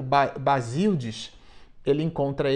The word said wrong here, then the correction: Basílide: esse companheiro, Basílide, é a forma mais correta de pronunciar.